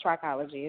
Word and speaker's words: trichology: And is Trichology not Trichology? – No, it's Trichology. It's the study of the hair and scalp. – Right. Okay. So the H is silent.